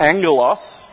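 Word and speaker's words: angelos (ἄγγελος): agalos, or produced angelos, is a better pronunciation of that, can equally be translated as messenger.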